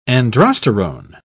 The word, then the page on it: androsterone: British English